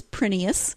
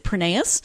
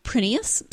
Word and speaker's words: Prineas: And she says it is Prineas. It is Greek. And actually in Greece, they would pronounce it Prineas. But when her husband's family emigrated, they changed it to Prineas.